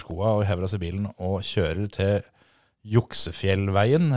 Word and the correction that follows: Luksefjell: eller hva som du uttaler det. L Juksefjell. Er det ikke Luksefjell? Luksefjell. Mm. Da kjører vi til Luksefjellveien. Ja.